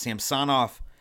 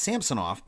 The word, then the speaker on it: Samsonov: I'm like, you know, it's pronounced Samsonov. And I'm like, no, it's Samsonov because, you know, the Bruins actually had a Samsonov back in the 90s.